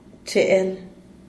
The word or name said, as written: cheel